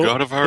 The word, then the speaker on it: Godavari: Godavari Damansi. – Godavari. Godavari. – So Godavari. Is that how we're pronouncing it? Godavari? Is that Yeah.